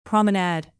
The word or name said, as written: promenade